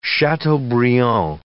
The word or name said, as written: chateaubriand